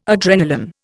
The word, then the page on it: adrenalin: Indian